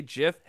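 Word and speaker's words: GIF: Senior Fat, I said, did you just say Jif? Hell yeah, I did. That's the way to pronounce it. That's what the creator wanted. I do what the creator says.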